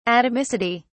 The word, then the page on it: atomicity: British English